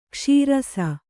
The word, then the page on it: ಕೈಸರಿಸ: kai sarisa; Kannada